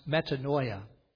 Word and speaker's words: metanoia: The Greek word for repentance is pronounced metanoia.